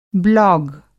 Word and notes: ब्लॉग: Hindi

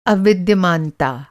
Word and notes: अविद्यमानता: Hindi

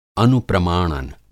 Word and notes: अनुप्रमाणन: Hindi

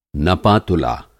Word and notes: नपा-तुला: Hindi

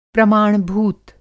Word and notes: प्रमाण-भूत: प्रमाणभूत

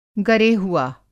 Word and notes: गरेहुआ: Hindi

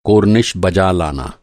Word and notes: कोरनिश: کورنش

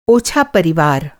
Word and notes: ओछा: اوچھا; Hindi

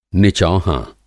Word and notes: निचौहाँ: Hindi

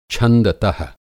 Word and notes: छंदत:: Hindi

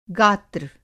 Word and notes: गात्र: Hindi

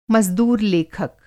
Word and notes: मज़दूर-लेखक: Hindi

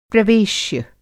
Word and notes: प्रवेश्य: Hindi

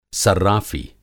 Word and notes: सर्राफ़ी: صرّافی

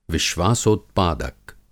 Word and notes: विश्वासोत्पादक: Hindi

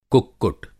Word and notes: कुक्कुट: Hindi

